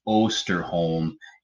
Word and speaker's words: Osterholm: She's the reporter that asked him a question, and I butchered that doctor's name. It's Osterholm, and he is the director of infectious disease research and policy.